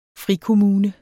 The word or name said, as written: frikommune